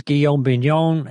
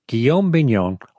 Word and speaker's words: Guillaume Bignon: It's like William in French. Did I get that right? Yes, I think I did. Yeah, G-U-I-L-L-A-U-M-E, last name B-I-G-N-O-N. That's like a big non.